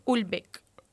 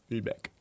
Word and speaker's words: Houellebecq: Com Ul pronunciem això? – Ulbeck. – Ulbeck.